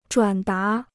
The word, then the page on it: 转达: zhuǎn dá